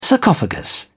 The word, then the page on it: sarcophagus: British English